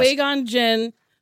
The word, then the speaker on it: Qui-Gon Jinn: Yes, Qui-Gon Jinn.